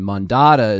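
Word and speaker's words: Mondatta: He's got Zen at the front, and Mandata is supposed to rhyme with regatta from the previous album.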